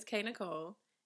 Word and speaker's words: K Nicole: So if you don't know me, my name is K Nicole, emphasis on the K, because the K is important, okay? Don't forget the K. My name is not Nicole.